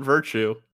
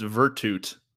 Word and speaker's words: Virtute: It's not Virtue. It's Virtute. Yeah, I know. Everyone says it wrong. I said it wrong most of the time. I had to repost our playlist because I spelled it fucking wrong. – Well, big fan. Okay. – It's a Latin word.